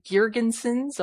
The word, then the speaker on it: Girgensons: I think they're third in the division right now, and they have alternate captains of Zagus Zeg Zem guess sorry Girgensons. Oh my God, butchered that one.